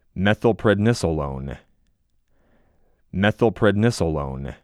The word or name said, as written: MethylPREDNISolone